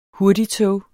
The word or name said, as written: HURTIGTOGES